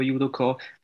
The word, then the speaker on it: Judoka: Du bist professioneller Judoka, wenn ich das richtig ausgesprochen habe, Judoka.